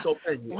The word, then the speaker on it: solfeggio: So one of the things that you're talking about using binaural beats, and I can't pronounce this, Solfeggio.